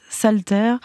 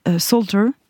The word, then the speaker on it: Salter: Vous avez choisi un extrait de James Salter, Salter. Comment vous prononcez ? – Oui, l'un ou l'autre.